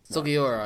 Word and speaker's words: Sugiura: Sugiura, I believe, is how it's pronounced.